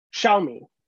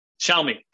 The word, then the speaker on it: Xiaomi: Is it Xiaomi? – No. It's I was on the tip of my tongue. – And then I fucked it up. – Yeah. Xiaomi. – Xiaomi.